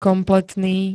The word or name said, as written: kompletný